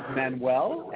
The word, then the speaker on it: Manuel: Dai is a Welsh name for David, and it's spelled D-A-I. And my last name is Manuel, M-A-N-U-E-L.